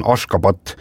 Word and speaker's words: Ashgabat: Jaa , selle pealinna tänapäevane õige kirjapilt ja hääldus on Ashgabat ja siis 1991.